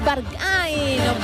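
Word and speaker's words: Berghain: La grandísima intacha es la de Rosalía, Barcay. – Es Barcay, no como cada uno lo quiera pronunciar, pero efectivamente es esta canción súper conocida, lo decíamos, actual y que suena mucho.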